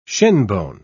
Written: shinbone

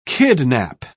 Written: kidnap

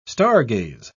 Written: stargaze

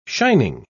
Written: shining

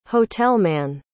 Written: hotelman

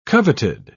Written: coveted

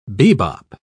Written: bebop

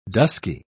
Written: dusky

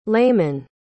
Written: leyman

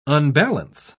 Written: unbalance

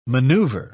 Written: maneuver

